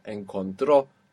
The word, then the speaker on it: encontró: We're ending in a vowel and we have the accent on the end. So all of these in that point in the past are going to have that accent. Encontró, habló, llamó, llegó.